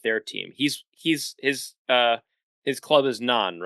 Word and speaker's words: Nantes: I don't think they pronounce the T in the T-E-S, so I think it's pronounced Nantes.